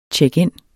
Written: check-in

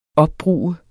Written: opbruge

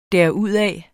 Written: derudad

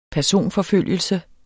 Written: personforfølgelse

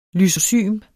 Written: lysozym

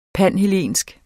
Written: panhellensk

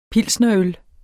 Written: pilsnerøl

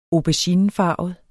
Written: auberginefarvet

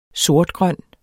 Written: sortgrøn

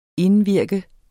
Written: indvirke

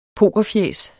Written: pokerfjæs

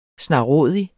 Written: snarrådig

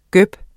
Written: gøb

